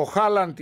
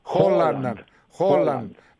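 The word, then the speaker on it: Haaland: Ναι, ο Χάλαντ ή όπως τον προφέρουν οι Νορβηγοί, Χόλαντ, ναι, 7.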